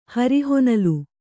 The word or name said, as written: ಹರಿಹೊನಲು